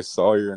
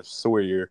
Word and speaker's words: Sawyer: Yeah, there's like two ways to pronounce it. Some people say Sawyer and some are Sawyer.